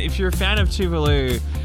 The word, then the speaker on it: Tuvalu: Apparently it is pronounced Tuvalu, but for years people would just see it and go, oh, it's Tovlo. – Yeah, Tovlo. That's a very Australian way to say it.